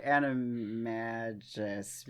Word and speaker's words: Animagus: So, like, if your loved one is a werewolf, you learn the, to become an animaga? How do you say that word? – I say animagus because it is animagus. Rooted in magician.